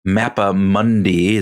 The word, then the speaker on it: Mappa Mundi: It's not the Mappa Mundi, is that how you say it? Uh, yeah.